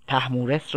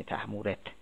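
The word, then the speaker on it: طهمورث: تحمورس رو داریم که البته دکتر خالقی اصرار داره که با سه نقطه نوشته بشه، یعنی تحمورت اینطوری تلفظ بشه که معروف میشه تحمورت دیوبند.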